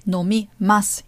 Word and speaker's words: nomimasu: As we hear, nomimasu, it ends with masu. It's actually ma and su, but we don't pronounce the u.